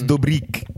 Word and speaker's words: Dobrik: Everyone's been pronouncing it wrong this whole time. It's Dobrik.